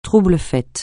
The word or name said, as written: trouble-fête